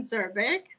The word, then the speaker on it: Zurbrigg: Because here with us is Jen Zurbrigg.